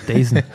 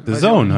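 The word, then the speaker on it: DAZN: Dazen. Wie heißt das? The Zone, sorry. – Okay. The Zone? – Wieso schreibt man das eigentlich Dazen? Das ist doch eigentlich Dazen. – The Zone heißt das.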